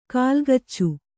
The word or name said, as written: ಕಲಗಚ್ಚು